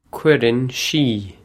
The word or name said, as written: cuireann sí